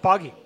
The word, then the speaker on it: Poggie: It's Poggie. – Yeah, it's Poggie. – Okay, you heard it here first. – Yeah, like the game. The kids play.